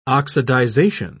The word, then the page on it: oxidization: British English